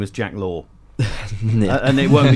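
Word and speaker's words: Laugher: I mean, the way it's spelled, it is Laffer, but yeah. – It is Law.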